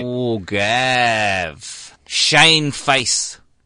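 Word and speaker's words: Faes: Oh, Face.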